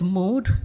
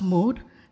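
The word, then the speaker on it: mod (מועד): Probably a better word is the original Hebrew word used in the Bible, and it's mod. It's actually spelled as moed, but it's pronounced mod, and it means appointed time or sacred season.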